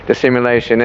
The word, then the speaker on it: dissimulation: that love be without dissimulation. Am I saying it right? No, dissimulation.